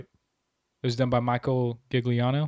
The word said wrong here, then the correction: Giacchino: Is that how you say his name? – Maybe. I don't know. – Michael Giacchino. – Yeah. – Sorry. The music was done by Michael Giacchino. – Or Giacchino.